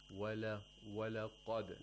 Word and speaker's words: وَلَقَدْ: Walaqad. – Walaqad. – Walaqad. The qaf is a letter of isti'la, because qaf, when pronouncing qaf, you have to raise the root of the tongue. Walaqad.